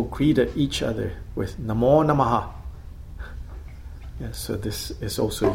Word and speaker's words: Namah: Namo Namah, even though it's transcribed as Namah, it's pronounced, I think, more like Namaha. There's a quick A after the H.